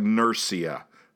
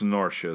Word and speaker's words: Norcia: Birra Norcia, and that's spelled N-U-R-C-I-A. It looks like Nursia. It's Norcia is how it's pronounced.